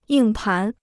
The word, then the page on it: 硬盘: yìng pán